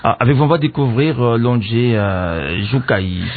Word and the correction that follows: Joukaï: Non, Joukaï, c'est en français.